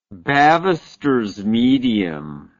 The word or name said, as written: bavister's medium